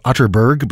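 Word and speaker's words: Atterberg: It's spelled Otterberg, but pronounced Otterberg, just in case you are Googling.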